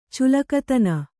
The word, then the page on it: ಚುಲಕತನ: kannada